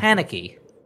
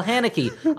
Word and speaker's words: Haneke: Haneke. – That's close enough. – No, it is not at all. One sounds like Hanky, and one sounds like Hanukkah.